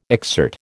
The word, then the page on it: exsert: American English